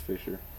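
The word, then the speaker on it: Fischer: Cillian Murphy's character, Robert Fichtner, It's pronounced Fisher.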